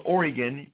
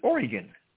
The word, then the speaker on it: Oregon: We know you're not from Oregon because if you're from Oregon, you pronounce it Oregon.